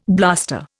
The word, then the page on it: blaster: Indian